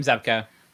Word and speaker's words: Zabka: Yeah, William Zabka.